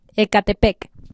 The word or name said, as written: Ecatepec